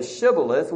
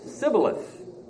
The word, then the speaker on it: Shibboleth: The men on the west side of the Jordan, they could not say Shibboleth. What did they say? Sibboleth. They couldn't say the sh sound, the sh sound.